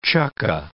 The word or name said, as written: chukka